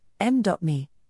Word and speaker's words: Mimi: Typhoon Mimi, pronounced M.Mi, known in the Philippines as Typhoon Porgy,